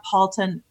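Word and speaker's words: Palten: Just go to Andrea Palton Coach.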